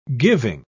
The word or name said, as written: giving